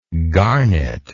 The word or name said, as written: garnetlike